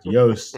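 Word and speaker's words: Joost: And we were talking, and he asked me how to pronounce my name. And I said, Yoast. And he said, okay, so it's really toast with a Y. And I was like, yeah, that's about it.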